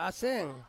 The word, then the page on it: aseng: Ansus